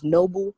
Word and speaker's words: Noble: First off, my name is Noble. That's spelled N-O-B-L-E. Jules, J-U-L-Z.